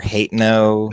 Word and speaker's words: Hateno: Like, my favorite town music is, and I'm going to say it wrong, Hotno or Hateno. – Latino. – There you go. – It's like Latino, except with an H.